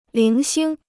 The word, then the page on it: 零星: líng xīng